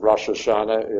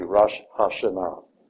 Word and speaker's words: Rosh Hashanah: Just like I told you last week, our word of Rosh Hashanah is improper pronunciation, but even the Jewish people use it that way. Technically, it should be Rosh Hashanah. Yeah. The accent is always on the A-H part, which is the last symbol in many of the important words of Jew, and it is in reference to God himself.